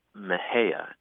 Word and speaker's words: Mexia: We have a funny town here called Mejia, and it's spelled M-E-X-I-A. – Mm. – And I think if you're an out-of-towner or a Yankee, you might be tempted to say Mexia, right? – Mm-hmm.